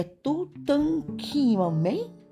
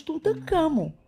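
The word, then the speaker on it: Tutankhamun: Ei, quer dizer que seu nome, na verdade, é Tutankhamen? questionou Breno. Mas pronunciamos Tutankhamon, respondeu o Faraó.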